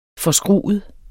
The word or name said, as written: forskruet